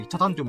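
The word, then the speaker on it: 北谷: ョっての、あ、ごめん、北の谷とかでチャタンって言うんだけどさ。あ、そうそう、そうだよね。チャタンって言うと、え、どういう漢字書くのと思った、思ったよね。あの、北の谷って書いてチャタンって読むんだよ。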